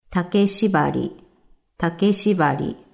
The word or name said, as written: Take shibari